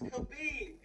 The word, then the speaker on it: Khabib: Is it Khabib? – Khabib? – It's Khabib, guys. – Khabib. I'm sorry.